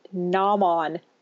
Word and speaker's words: Gnomon: I'm thinking it's Gnomon, but it's spelled G-N-O-M-O-N. – Gnomon. – No, see? – Because gnome is G-N-O-M-E. – Oh, yeah, you're right. – So Gnomon is how I would say it, but Gnomon works too.